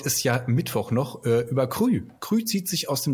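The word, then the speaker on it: Kry: Könnte man auch Krei aussprechen, aber das steht extra auf der Webseite schwedisch, warum man das, also das ist Krü ausgesprochen.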